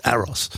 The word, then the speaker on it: AROS: This is an operating system that is called, I believe you pronounce it AROS. I used to call it AROS, but someone corrected me once. It's not AROS, it's AROS.